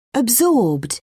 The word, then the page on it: absorbed: British English